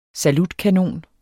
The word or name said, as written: salutkanon